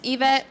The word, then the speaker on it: Yvette: It's Yvette, not Yvette, with a Y, not an E.